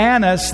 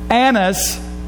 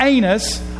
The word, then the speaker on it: Annas: The next day, their rulers, elders, and scribes assembled in Jerusalem with Annas, the high priest. Now that is pronounced Annas, not Anus, although that might be applicable later in the story, but it's Annas, the high priest.